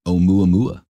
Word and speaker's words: Oumuamua: How do you say that? – Oumuamua.